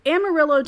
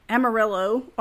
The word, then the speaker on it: Amarillo: Amarillo, or I should say Amarillo, is yellow in Spanish, but the pronunciation of the city's name has slowly changed to the non-correct Amarillo over time.